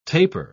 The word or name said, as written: taper